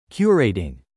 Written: curating

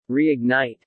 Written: reignite

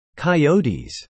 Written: coyotes

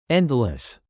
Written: endless

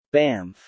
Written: Banff

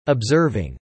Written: observing

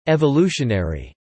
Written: evolutionary

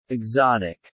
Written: exotic

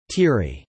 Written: teary

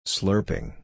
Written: slurping

